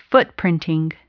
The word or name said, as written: footprinting